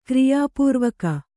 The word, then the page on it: ಕ್ರಿಯಾಪೂರ್ವಕ: kriyā pūrvaka